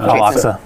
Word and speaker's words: Al-Aqsa: Al-Aqsa.